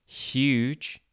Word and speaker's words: huge: If you pronounce it very slowly and exaggerate the sounds, huge, you can hear it at the beginning. We actually pronounce this H not as h, but as sh, so huge.